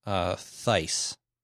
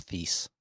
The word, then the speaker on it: Thijs: Next email comes from, I believe this name is pronounced Thijs. It's Thijs in the Netherlands. And I looked it up. And one website said that it rhymes with nice.